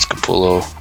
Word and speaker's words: Capullo: How'd you get that of Capullo? – Capullo, Capella. – No, acapella. – I'm pretty sure it's Capullo.